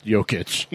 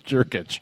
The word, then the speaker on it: Jokic: Jurkic, and and we, as I pointed out on the podcast, Sandy Clough mispronounced Jokic and called it Jurkic.